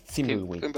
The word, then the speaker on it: Thimbleweed Park: Thimbleweed Park. ¿Cómo me dais? – Thimbleweed.